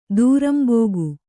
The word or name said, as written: ದೂರಂಬೋಗು